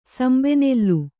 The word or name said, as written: ಸಂಬೆನೆಲ್ಲು